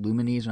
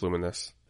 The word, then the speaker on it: Lumines: I'm always confused by the name Luminous, because like it's spelled Lumens. I used to pronounce it Lumines when I was an idiot and a kid. But like, wow. I don't know what's. Is it Luminous? – Yeah, it's Luminous.